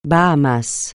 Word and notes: Bahamas: francais

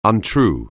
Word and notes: untrue: anglais

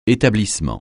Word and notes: établissement: français

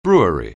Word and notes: brewery: anglais

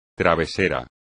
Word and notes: travesero: espagnol

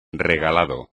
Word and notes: regalado: espagnol

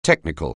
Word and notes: technical: anglais

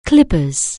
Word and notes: clippers: anglais

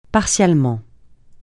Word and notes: partialement: francais

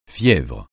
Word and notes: fièvre: francais

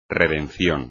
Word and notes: redención: espagnol

